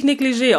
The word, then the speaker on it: Negligé: Aber Negligé wird wirklich Negligé ausgesprochen.